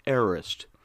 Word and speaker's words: aorist: It is spelled A-O-R-I-S-T, and it is pronounced aorist, pronounced aorist. The A is from the alpha, which means not. The O-R-I, the O-R-I,